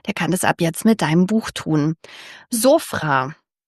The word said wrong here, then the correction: Sofra: Nein, Moment, man spricht es anders aus. Kannst du es nochmal aussprechen? – Sofra. – Sofra. Aber ist die Betonung am Ende und man macht ein rollendes Rhythmus? Ja, in türkischen Wörtern ist die Betonung oft am Ende.